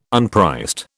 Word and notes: unpriced: British English

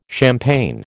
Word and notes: champaign: American English

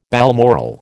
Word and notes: balmoral: American English

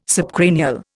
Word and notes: subcranial: Indian

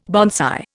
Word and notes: bonsai: Indian

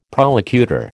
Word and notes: prolocutor: American English

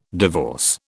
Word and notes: divorce: British English

